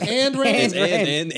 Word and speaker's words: Ayn Rand: It's A-Y-N, isn't it? – Yeah. – I thought it was And. – Oh, it's and Rand. – And Rand, yeah.